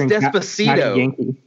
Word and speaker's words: Despacito: So I just looked up what the most watched video of all time on YouTube is, and it's Despacio by Louis, I can't even pronounce that name. Louis Fonsi. – Oh, that's Despacito.